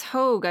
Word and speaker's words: Hoegg: So Justice Hoge, I believe it's pronounced, H-O-E-G-G,